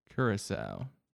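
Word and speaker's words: Curaçao: I think it's pronounced Curacao. – Curacao. That makes sense.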